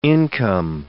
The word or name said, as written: income